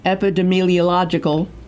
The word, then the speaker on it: epidemiological: In recent epidemiological, did I say that correctly? – You did.